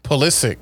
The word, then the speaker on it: Pulisic: Pulisic, how you say it? – Pulisic.